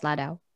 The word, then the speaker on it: Ladao: Uh, and I'm with me is one of our fulfilling destiny interns, Jas Ladao.